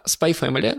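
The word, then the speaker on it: Spy×Family: Spy Family называется mm-hmm. – Spy X, который не читается Family.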